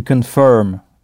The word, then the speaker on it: confirm: To confirm. C-O-N-F-I-R-M. Donc avec I-R qui se prononce E. To confirm. Et arriver se dira to arrive. – To arrive. A-2-R-I-V-E.